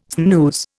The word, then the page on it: snooze: Indian